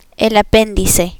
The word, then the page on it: apendice: Spanish